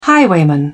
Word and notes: highwayman: British English

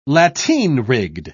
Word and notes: lateen-rigged: British English

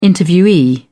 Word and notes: interviewee: British English

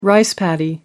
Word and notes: rice paddy: American English

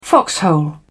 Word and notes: foxhole: British English

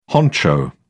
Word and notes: honcho: British English

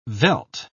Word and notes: veldt: British English

